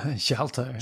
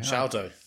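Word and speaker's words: Sharlto: Well, you say his name better, don't you? – Sholto. – Sholto. Sholto Copley, Dev Patel, Hugh Jackman, Ninja, Yolandi Visar, Jose Pablo Cantillo, I think, and the one and only Sigourney Weaver.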